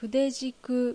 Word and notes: Fudejiku: Japanese

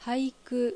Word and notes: Haiku: Japanese

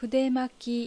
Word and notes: Fudemaki: Japanese

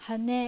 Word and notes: Hane: Japanese

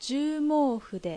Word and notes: Juumoufu: Japanese

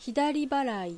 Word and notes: Hidariba: Japanese

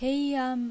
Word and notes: Heian: Japanese

